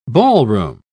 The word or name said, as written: ballroom